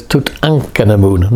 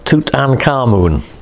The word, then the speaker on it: Tutankhamun: And so, you know, fast forward 20th century, Howard Carter, Tutankhamun, whose proper pronunciation is Tutankhamun, not Tutankhamun. Tutankhamun. Tut, whose life is held in Amun. Ankh is the word meaning life, Amun meaning the god Amun.